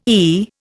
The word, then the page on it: e: Indian